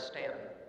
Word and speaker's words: Staunton: Not too many months ago, our family spent some spring break time in the town of Stanton, Virginia, which, by the way, it didn't take me long to learn is Stanton, even though it's spelled like something else.